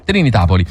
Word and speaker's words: Trinitapoli: Trinitapoli.